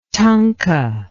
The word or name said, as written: tunka